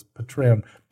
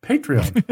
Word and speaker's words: Patreon: I always mispronounce it as Patreon. Patreon, I guess.